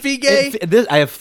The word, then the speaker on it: Feige: How many different pronunciations of his goddamn name do I have to get? Is it Feige? – I've looked it up, and I believe the answer is Feige.